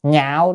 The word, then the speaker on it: nhạo: Nhạo đây là ưa thích, chữ này đọc là nhạo, phải đọc là nhạo cho nên là lạc được.